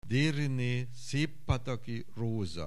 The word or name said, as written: Deryne Szeppataki Roza